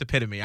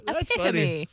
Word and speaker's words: epitome: And then I'll be like, oh, no, it's epitome. I know that now. – Epitome.